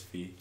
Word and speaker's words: phi: which I thought was interesting because it kind of sounds like pi, but it's phi.